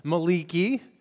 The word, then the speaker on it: Malachi: So would you open up with me, join me in Malachi, or if you prefer the Hebrew pronunciation, Maliki.